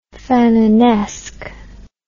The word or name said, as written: Fanonesque